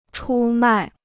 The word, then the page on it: 出卖: chū mài